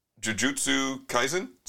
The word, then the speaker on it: Jujutsu Kaisen: Okay, speaking of anime, Sandwich, our resident anime expert, Jujutsu Kaisen season 2? – Yes.